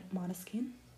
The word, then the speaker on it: Måneskin: And so Måneskin, that yesterday discovered that correct pronunciation Måneskin,